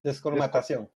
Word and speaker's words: descolmatación: Eh, se, genera un presupuesto y se realiza un trabajo de, corrígeme si lo digo mal, descolmatización, ¿es? – Descolmatación. – descolmatación en un lugar que va a volver a,